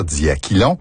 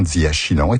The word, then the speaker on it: diachylon: En principe, il faudrait dire diaquilon, mais la prononciation diachylon est acceptable.